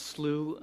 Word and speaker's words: slough: It's pronounced slough on the prairies.